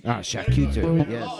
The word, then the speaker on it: charcuterie: What, how do you pronounce that? – Charcuterie.